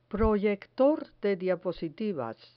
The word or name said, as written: proyector de diapositivas